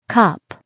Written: cop

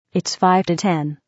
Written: 5 to 10

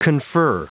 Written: confer

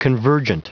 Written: convergent

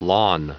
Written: lawn

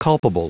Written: culpable

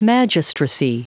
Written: magistracy

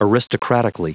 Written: aristocratically

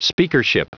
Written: speakership

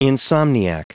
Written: insomniac

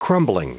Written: crumbling

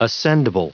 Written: ascendible

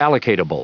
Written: allocatable

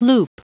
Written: loop

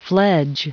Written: fledge

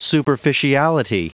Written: superficiality

